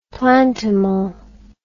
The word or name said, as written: plantimal